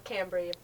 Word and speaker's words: Cambrie: Cambrie.